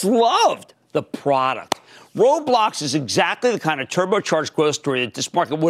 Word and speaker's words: Roblox: And it's called Roblox. And you pronounce the O like O.